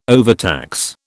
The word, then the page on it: overtax: British English